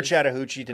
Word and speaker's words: Chattahoochee: You've never Chattahoochee'd, and don't start acting like you do. – Made love and then we cried. – Speaking of Chattahoochee, rhymes with Mariucci, former coach of the 49ers in San Francisco, Steve Mariucci.